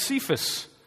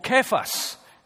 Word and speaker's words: Cephas: And he's describing an interesting little interaction here that he had with Cephas, or Cephas, if you want to the correct pronunciation, who is Peter, the apostle.